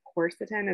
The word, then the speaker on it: quercetin: And the one that you just mentioned, I'm probably going to say it wrong, but quercetin, is that how you say it? – Yeah.